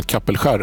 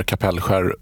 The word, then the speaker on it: Kapellskär: På E18 mellan Kapellskär eller Kapellskär, vilket uttal du nu föredrar.